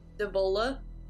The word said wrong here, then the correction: Diavola: Davolia. What is it? – Diavola. Diavola.